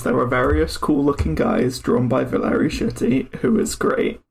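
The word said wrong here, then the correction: Schiti: Don't say Shitty. – Oh yeah, Valeri Shitty. – It's Skeety. – Skeety. – Yeah. – I always want to pronounce the H. – Yeah, it's the opposite as in mistake. – German. Like, in German, the S-C-H is the sh... But in Italian, the S-C-I would be she.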